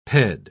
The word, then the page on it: ped-: British English